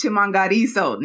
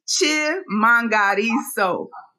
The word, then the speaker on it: Chimhangariso: My last name is Chimhangariso now. – Oh, I am so sorry, Chimhangariso. One more time. – Can we get that pronunciation one more time? – It's Chimhangariso.